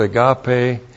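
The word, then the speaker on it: agape (ἀγάπη): And then we have hey agape to theu, that's the love of God, but in the Epistle of John, the word agape is used in John, A-G-A-P, long E, for virtue love.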